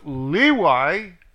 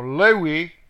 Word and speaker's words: Levi: And you shall know that I have sent this commandment, 4, 6, 8, 7, to you, that my covenant might be with Levi, or Lewi, there's two pronunciations, says Yahweh of hosts, the Levites, okay?